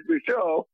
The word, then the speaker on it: Michaud: Ron Holmes set me straight on that. He said, it's Michaud. I said, okay. And so anyway, I thought it was just, you know, Michaud.